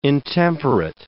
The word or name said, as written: intemperate